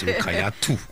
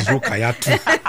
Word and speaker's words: Rukayatu: I feel like one of the reasons why I even go with Ruki is because people pronounce my name wrongly, like everywhere. Like everybody have different pronunciations of Rukayat. Some people will call it Rukayatu. Some will say, and I just like Ruki. – But for me, it's Rukayatu.